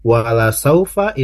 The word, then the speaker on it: wala saufa: Tadi adik Daniel bacanya wala saufa, la-nya dipanjangin. Nah, harusnya wala saufa itu pendek ya. Itu jadi hati-hati. Memang terdengarnya seperti panjang, padahal memang aslinya tidak panjang.